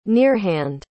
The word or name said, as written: nearhand